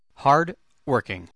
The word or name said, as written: hardworking